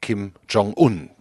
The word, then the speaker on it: Kim Jong-un: Seine Gedanken bewegen sich also irgendwo zwischen Frankfurter Römer, Kommunalpolitik und Pyongyang, Kim Jong-un. Das ist die korrekte Aussprache, habe ich gerade selbst auch einmal falsch gemacht.